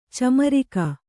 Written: ಚಮ್ಮರಿಗೆ

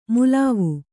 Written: ಮಲುವು

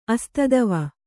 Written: ಅಸ್ತಿತ್ವ

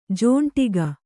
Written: ಜೊಂಡಿಗ

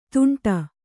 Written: ತುಂಟ

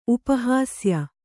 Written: ಉಪಹಾಸ್ಯ

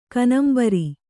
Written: ಕನಂಬರಿ

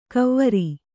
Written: ಕವ್ವರಿ

